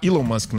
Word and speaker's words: Elon Musk: Correto falar Elon Musk, né? Que é Elon. – É. Isso, ele é sul-africano. É, é, é a pronúncia.